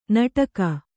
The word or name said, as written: ನಟಕ